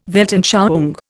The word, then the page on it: Weltanschauung: Indian